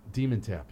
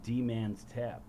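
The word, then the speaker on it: Dmen: You say it like demon. – Yeah. – I always said D-man's tap or something like that, right? Well, how's it spelled? – It's D and then M-E-N tap.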